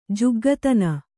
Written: ಜುಗ್ಗುತನ

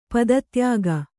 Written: ಪದತ್ಯಾಗ

